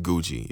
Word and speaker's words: Guji: They have this this new roast called Guji or Giji. I don't know if I'm pronouncing it right. I think it's Guji. It's a G-U-J-I.